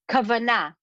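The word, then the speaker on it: kavanah: In Hebrew there's a word uh kavana or pronounced actually that's that's what the Hebrewish uh, accent to it, but it's kavana.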